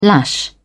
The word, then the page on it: lash out: British English